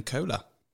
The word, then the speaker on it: Kohler: Our very special guest tonight, all the way from the States in Las Vegas, is Florian Kohler.